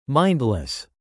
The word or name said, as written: mindless